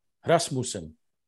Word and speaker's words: Rasmussen: Rasmussen.